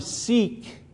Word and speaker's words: Sikh: It was the term Sikh, by the way, that's how you pronounce this, Sikhism.